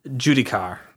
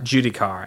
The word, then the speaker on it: Giudichar: But Judikar. – Judikar? – Judikar. And I'll spell it for you here. – Sure. – G-I-U-D-I-C-H-A-R.